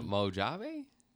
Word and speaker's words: Mojave: Mojave? How do you say that? Mojave Desert? – Oh, M-O-J-A-V-E. – Yeah, Mojave? No, I sound dumb. – I'm not even going to say it. – Mojave, right? – Yeah, that's it.